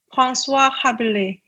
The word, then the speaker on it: François Rabelais: So it's François Rabelais.